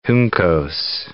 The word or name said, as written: Juncos